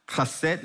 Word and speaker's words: Chesed: Chesed ve'amet. Say chesed ve'amet. Anyone knows what's chesed? Oh, love this church. One more time. What is chesed? Okay, we spell it H-E-S-E-D. Chesed.